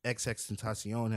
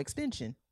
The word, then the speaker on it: XXXTentacion: XXXTentacion has timeless music. – Is that how you say his name? I always call him Extension.